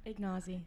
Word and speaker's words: Ignozzi: Ignozzi.